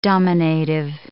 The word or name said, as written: dominative